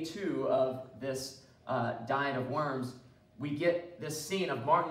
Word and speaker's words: Worms: We think of Martin Luther at the Diet of, I pronounce it the Diet of Worms because I'm not German, so I don't say Worms,